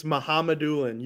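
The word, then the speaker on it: Mukhamadullin: Mohammedullin, I think. – You're so close. It's Mohammedullin.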